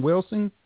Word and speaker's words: Welsing: Is that how you pronounce the name, Welsing? – Yeah, she's a practicing psychiatrist.